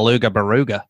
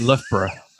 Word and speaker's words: Loughborough: There was an Australian once, so there's a place called Loughborough. Everything's Borough here, yeah. And he was like, excuse me, do you know the way to Luga Baruga? No, it's Loughborough.